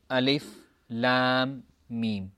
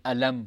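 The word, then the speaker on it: Alif, Lam, Mim (الم): And for this reason, we pronounce it Alif, Lam, Mim. We don't say Alam, for example. Rather, we pronounce them as individual letters.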